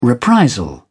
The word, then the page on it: reprisal: British English